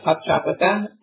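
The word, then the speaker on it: Poptropica: And today is the 3-year birthday of Pop Tropica, and Lila likes to play it, and she's 9 years old.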